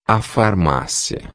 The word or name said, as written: farmacia